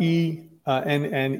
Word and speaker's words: Enneagram: So Enneagram, spelled E N-N-E-A-G-R-A-M, pronounced Enneagram, like A-N-Y-G-R-A-M.